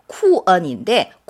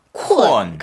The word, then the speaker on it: 困: 이거예요. 쿠언인데 쿠언, 쿠언. 그런데 그걸 빨리 발음하면 쿠언, 쿠언처럼 들려요.